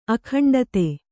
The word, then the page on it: ಅಖಂಡತೆ: akhaṇḍate